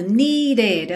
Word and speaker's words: needed: E atenção aí para esse needed, porque esse é aí do meio é pronunciado needed. Essa palavra tem mesmo duas sílabas, ok?